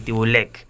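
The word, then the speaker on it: Țîbuleac: cuya autora se llama Tatiana Tibulek o Tibuliak. ¿Cómo se pronuncia? ¿Vos lo tenés más claro? – Yo creo que es así, Tibulek.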